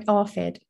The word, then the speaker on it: ARFID: So today's podcast is all about, and I hope I'm pronouncing it right, is it ARFID? – That's right, ARFID.